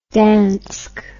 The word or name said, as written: dansk